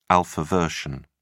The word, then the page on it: alpha version: British English